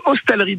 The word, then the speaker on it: hostellerie: Alors déjà, est-ce qu'on prononce hostellerie ou hôtellerie ? – Alors on prononce hostellerie de Le Vernois.